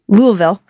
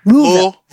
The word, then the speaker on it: Louisville: Louisville. I'm not saying it right. Louisville. – You're saying you're saying it close.